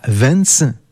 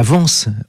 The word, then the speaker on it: Vence: Lawrence est décédé en 1930 à Vence, non, mauvaise prononciation, à Vence, autre ville d'artistes dans les Alpes-Maritimes.